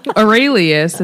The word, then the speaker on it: Aurelius: So Aurelius, is that how you say it? – Yes.